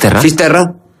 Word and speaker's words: Finisterre: Finisterre.